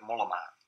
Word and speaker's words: Mollema: Bauke Mollema.